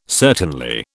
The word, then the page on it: certainly: British English